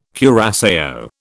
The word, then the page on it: curaçao: British English